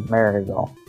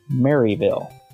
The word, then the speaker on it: Maryville: And if the listeners would notice how Mike said Maryville, now most people, if they were coming into the area, would pronounce it Maryville, right? – Because it's Maryville. – But looks like Maryville. – If you pronounce it that way to the locals, they'll look at you like you're a dandy.